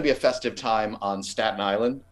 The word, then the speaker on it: Staten Island: We're going to be beating each other to death with the T's that are supposed to be pronounced in Staten Island, but are not. Staten Island.